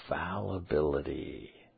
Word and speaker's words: fallibility: Am I saying that right? Fabilities? What does fability mean? Hold on. I'm looking it up. Copy, paste, enter. Fability. The tendency to make a mistake. Oh, fallibility. My bad.